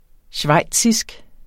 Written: schweizisk

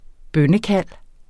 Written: installationsprogram

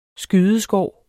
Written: skydeskår

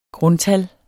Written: grundtal

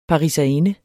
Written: pariserinde

